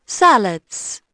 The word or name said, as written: salads